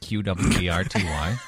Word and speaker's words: QWERTY: QWERTY. Yeah. – What does QWERTY stand for? – QWERTY is the American English keyboard layout. – All right. – Look at the keyboard. It says QWERTY.